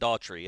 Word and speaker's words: Dougherty: Uh, but they're going to bring in the uh, the young man from Dougherty, Georgia. What uh, Kam Davis? – So yeah. I, am excited to see Daughtry. What, I think we Daughtry? pronounce it Daughtry.